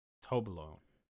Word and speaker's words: Toblerone: Okay, we can put it below, below the, the, How do you say that one? Toblerone. Toblerone. – Tobe. Tobe. Larone. Larone. Tobe yep. Larone.